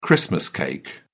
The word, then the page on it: christmas cake: British English